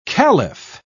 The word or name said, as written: kalif